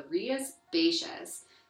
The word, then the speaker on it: Rías Baixas: The first one, and the one we're going to talk the most about in this first half, is the Rías Baixas, which is probably the most famous.